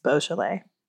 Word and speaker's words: Beaujolais: I said Beaujolais because I thought it was bougie, but it's Beaujolais.